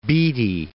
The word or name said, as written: Beattie